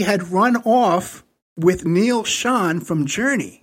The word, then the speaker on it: Schon: Neil Sean, S-C-H-O-N.